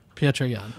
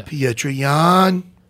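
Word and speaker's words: Petr Yan: And he pronounced, I know it is Peter, but he pronounces it Pietra. – Pietra? – Yeah. – Jan? – Pietra Jan. Yeah. – Pietra Jan.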